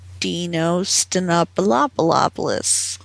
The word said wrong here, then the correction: Dino Stamatopoulos: Sorry, Dino Stamatopoulos. – Dino Stamatopoulos.